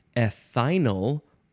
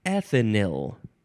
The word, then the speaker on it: ethinyl: Next is, you can pronounce it either ethinyl or ethanyl. Ethanyl. Yep. Ethanol or ethanyl. You can spell it E-T-H-I-N-Y-L, or you can replace the I with a Y.